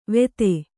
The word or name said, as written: ವೇಧೆ